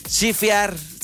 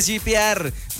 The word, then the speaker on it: shipear: P-O-V. Chifear. ¿Sí se pronuncia así? ¿Chifear o chipear? Chipear. ¿Chipear? ¿Chipear? Ay, ni siquiera sé cómo se pronuncia. ¡Cállense! ¡Cállense! Ustedes ahí, los que me están escuchando, también se rieron, pero de tristeza porque ya están grandes. Dice shipear. Cuando quieres que dos personas sean pareja, lo shipeo muchísimo.